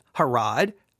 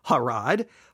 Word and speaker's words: Harod: Place Harod. That's the right way to say it. Harod, right? Harod. Place meaning quake or terror.